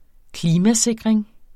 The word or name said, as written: klimasikring